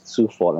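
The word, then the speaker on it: Coufal: Kufal and they pronounce it Sufal and Suchek, the two Czech boys.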